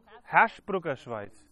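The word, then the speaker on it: Hersbrucker Schweiz: Bruckerschweiz. Herschbrucker Schweiz. So schön, ja. Habe ich schon mal falsch gesagt, aber.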